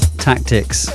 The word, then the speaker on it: Taktix: under the name Shock Tactics. That's right, it's spelt in a weird way. Shock Tactics. T-A-K-T-I-X.